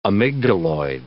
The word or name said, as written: amygdaloid